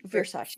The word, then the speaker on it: Versace: I started watching The Assassination of Gianni Versace or Versace? – Yes, Versace. – Yeah. Yeah, I don't know how to say the name because apparently like the bourgeois or like Versace, and then the common folk are like Versace, and I'm like, I don't know what to say.